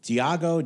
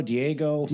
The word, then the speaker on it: Diageo: How do you say, what is it, Diego? – Diageo. – Okay, Diageo.